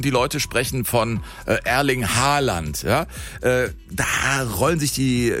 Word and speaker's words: Haaland: Er wird ausgesprochen Haaland, also mit O, ja. – Haaland. Und zwei A's werden als O ausgesprochen und ein A mit einem Kringel oben drauf ist eigentlich auch eine Aussprache, ein O.